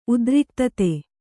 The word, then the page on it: ಉದ್ರಿಕ್ತತೆ: Kannada